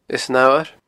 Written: Eas an Fhamhair

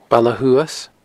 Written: Baile Shuas

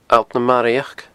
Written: Allt na Maraidheachd

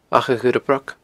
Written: achadhurrabroc

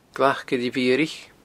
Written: glacillemhiaraich

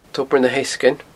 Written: tobar na heasgainn

